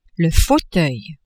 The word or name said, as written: fauteuil